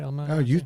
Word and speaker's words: El Mariachi: Am I saying that right, El Mariachi? – Oh, you sound fantastic.